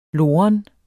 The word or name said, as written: loren